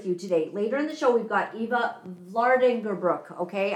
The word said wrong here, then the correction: Eva: Oh, and I said it wrong already. It's Ava.